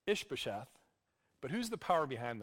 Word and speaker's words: Ish-bosheth: Okay, meanwhile, Abner, son of Ner, the commander of Saul's army, had taken Ish-bosheth, that's how you pronounce it, Ish-bosheth,